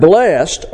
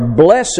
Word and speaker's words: blessed: The word blessed, or blessed is the poetic pronunciation, isn't equal to perfect earthly happiness.